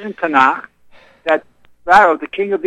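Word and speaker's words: Tanakh (תנ״ך): If you look in Tanakh, as it pronounced properly, Tanakh, the Book of Kings and the Book of Kings, the story of King David and Solomon.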